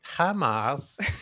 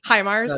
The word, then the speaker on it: HIMARS: Is it HIMARS? – HIMARS. – I'm not going to do the Tommy Wiseau impression. – HIMARS.